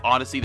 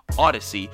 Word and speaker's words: Audacy: and the app Odyssey, that's spelled A-U-D-A-C-Y, Odyssey.